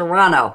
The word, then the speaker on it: Toronto: Okay, I've learned to not pronounce the second T in Toronto. Toronto.